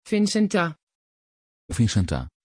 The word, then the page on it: vincenta: Dutch